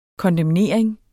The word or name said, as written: kondemnering